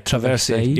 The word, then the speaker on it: Traversier: I think the track in it that really jumped out to me was number three, which, again, excuse my pronunciation, was it Traversier? – Yeah, Traversier, yeah.